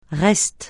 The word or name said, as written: reste